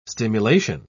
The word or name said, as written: stimulation